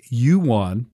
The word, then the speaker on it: yuan: Now, they said that they're going to pay up to 10,000 Chinese yuan. That's how you pronounce that.